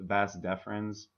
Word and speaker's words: vas deferens: And so there's a part, there's like a duct in a wiener called the vas, actually in the balls. I'm not even pronouncing it right. The vas deferens.